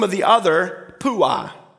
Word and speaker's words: Puah: The emphasis is on the end, on the awe. All right, say it with me. Shiprah, Puah. Shiprah and Puah.